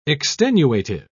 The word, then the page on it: extenuative: British English